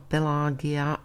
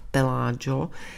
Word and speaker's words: Pelagio: Podobně se týden Gay Pride ve Španělsku, ne Gay, ale Gay Pride ve Španělsku, každoročně kryje se svátkem svatého Pelagia. Já jsem to před chvílí četla Pelagia, ale zřejmě by se to mělo vyslovovat Pelagio.